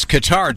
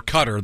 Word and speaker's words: Qatar: It's Qatar, Donnie, or Cutter. They're both pronunciations are acceptable.